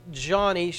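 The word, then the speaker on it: Joni: It's spelled Johnny, but it's pronounced Johnny.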